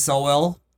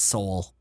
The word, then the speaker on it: Sowell: I put Thomas Sowell. – Okay. Who? – Actually, that's not even how you say his name. I think it's Sowell.